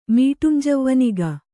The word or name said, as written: ಮೀಟುಂಜವ್ವನಿಗ